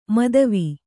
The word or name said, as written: ಮೋದವಿ